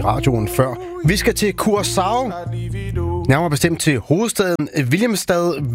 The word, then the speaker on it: Curaçao: Ja, hvis du må tage det helt korrekt, så siger det, hedder Curaçao. Det der C med den lille krølle under, det får sådan en sjav-lyd, men langt de fleste, de udtaler blot som et S, C, så Curaçao.